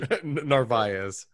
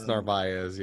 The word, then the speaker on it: Narváez: Probably butchering that pronunciation. – Narváez. – You know, I've read his name so many times, I know how to spell it, but I've just never said it. – It's Narváez, yeah.